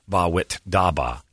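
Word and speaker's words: Bawitdaba: Ba wit da ba, which I'm just now learning is how you say that.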